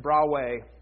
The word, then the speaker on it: Broadway: Mike Metzler's a pastor at Zion Mennonite Church in Broadway, which is how you say it if you're from Broadway.